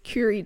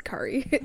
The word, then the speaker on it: Curie: What would Marie, is it Curie? Curie do. I said Curry in my mind.